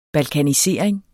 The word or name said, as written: balkanisering